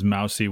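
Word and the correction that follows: moussy: which I might retract and call it moosey now after reading this, but it's resembling or characteristic of mousse, especially in texture.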